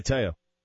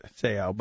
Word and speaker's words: Te'o: Manti Te'o. – I thought it was Manti. – Well, it's the last name that I have trouble with because I pronounce it like Seo, and it's not Seo, but regardless.